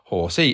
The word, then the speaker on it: Horsey: Maria Wicks, I am so disappointed Horsey isn't pronounced like a horse.